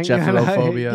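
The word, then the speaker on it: gephyrophobia: Did I say it right? Jephrotol, whatever. – Jephirophobia. – If it's a real thing. – Jephrophobia. – Yeah, that sounds about right.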